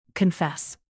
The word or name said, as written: confess